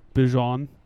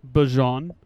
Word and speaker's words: Bijan: You see the Big 12 pronounced Bijan's name, Bijan. Bijan.